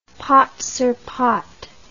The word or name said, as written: pate sur pate